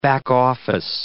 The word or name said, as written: back-office